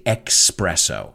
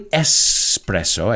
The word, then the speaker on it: espresso: Well, you know, you've got the classics espresso, which a lot of Americans mispronounce and they say espresso. But it's really espresso, as you guys know.